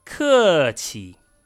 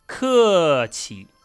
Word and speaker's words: 客气: 客气 means polite or to be polite. 客气. Here, 气 is pronounced in the neutral tone.